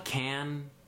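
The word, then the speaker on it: Cannes: I don't like Can, the festival, because half of the words in the word can you don't pronounce. – Half of the letters.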